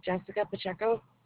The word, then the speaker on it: Jessica Pacheco: Jessica Pacheco. – Said it, Jessica. J-E-S-S-I-C-A.